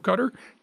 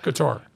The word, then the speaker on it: Qatar: It is so not Qatar. – Right. Well, and some people do pronounce it that way. – And they're wrong.